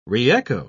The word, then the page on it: reecho: British English